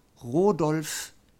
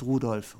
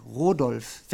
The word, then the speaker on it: Rodolf: Georg Rodolf, nicht Rudolf, Rodolf Weckerlin hat sie vor ziemlich genau 400 Jahren in die deutsche Prosodie eingeführt, Ruhm seinem Angedenken.